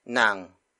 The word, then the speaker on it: ng: And then after that, it's nang. Nang. Nang. Nang is spelled as N-G.